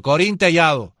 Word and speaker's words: Corín Tellado: Corinne Tellado. – Hay que suspirar aquí. – ¿Tampoco lo dije bien? – No. – Bueno, Corín Tellado.